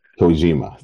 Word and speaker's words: Kojima: Satoshi Kojima. – Kojima, thank you.